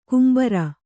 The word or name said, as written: ಕೆಂಬಾರ